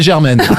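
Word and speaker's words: Jermaine: Germaine, un homme. Vous l'avez mal prononcé. – Si vous avez dit Germaine, c'était moins drôle. – En plus, je le connais, il n'aime pas du tout qu'on l'appelle Germaine.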